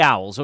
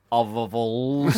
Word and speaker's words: OVVLS: We say Owls. I was looking at it, and it's spelled O-V-V-L-S. Yeah, so I spent about 5 minutes going, ovavals. And it was only when Gibbo walked in, I said, how do you pronounce this?